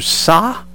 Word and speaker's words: Sa: His name is Andrew Sa, S-A. I believe that's the way you pronounce it.